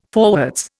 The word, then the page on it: forwards: Indian